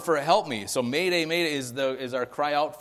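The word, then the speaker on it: m'aidez: Mayday. – What is it, Julie? Yeah. It means what? Mayday. It sounds like mayday, and it's a French word then for? – Help me, right?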